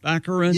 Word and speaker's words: Baccarin: Daredevil's girlfriend, Morena Baccarin? – Baccarin.